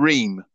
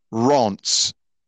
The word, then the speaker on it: Reims: Many British people would call this team Reims or Reims, okay? Right? Now, I had to Google this and I thought, how do you pronounce that? Is it Reims or Reims? It's actually not. It's actually pronounced Rance. What the hell is that all about? Rance, apparently. Spell R-E-I-M-S.